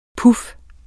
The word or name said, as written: puf